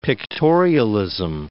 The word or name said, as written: pictorialism